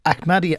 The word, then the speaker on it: Ahmadiyya: Well, what is the difference then between the Ahmadiyya, I'm sorry, I'm not saying it right. – You're pronouncing it right. – Ahmadiyya Muslim community and what you do in Clonskee.